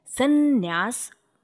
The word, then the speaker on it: संन्यास: पैदाइश के हिसाब से ये सम धन न्यास है जिसका उच्चारण समन्यास के समीकरण के कारण संन्यास यानी डबल न हो जाना अत्यंत स्वाभाविक है उच्चारण उपन्यास और विन्यास आदि का भी उपन्यास और विन्यास आदि है